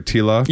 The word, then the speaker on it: Tila: You know, fighting with guys that would, he's having his one-on-one time with Tila. Is it, oh, am I saying this right? Tila? – Yes. – Okay. I didn't know if I was supposed to call her Tequila or something like this.